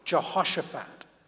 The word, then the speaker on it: Jehoshaphat: I will gather all the nations and bring them down to the valley of Jehoshaphat. Well done for your pronunciation there. It's hard work to say that.